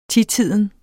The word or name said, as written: titiden